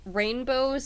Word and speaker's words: Rainbeaux: And then for the middle name, it's Rainbow, spelled rain and then B-E-A-U-X, because, you know, why not? It's a cool name. I like that name. – Spell it again. – Rain, R-A-I-N B-E-A-U-X.